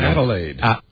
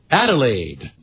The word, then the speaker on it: Adelaide: Adelaide. – Adelaide.